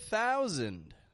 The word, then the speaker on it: thousand: Well, there's another word that I've just repeated, which you can do the same with, and it's the word thousand. Thousand. A wonderful example. Notice once more how I'm not pronouncing the S as an S. I'm pronouncing it as a Z, a Z, Z, voiced sound.